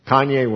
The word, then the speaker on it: Kanye: Kanye West.